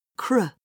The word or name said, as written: cr